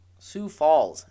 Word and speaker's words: Sioux Falls: Sioux Falls in South Dakota. Yeah, I said that right.